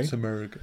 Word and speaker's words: Simurgh: S-I-M-U-R-G-H. Simurgh. Okay. – Simurgh.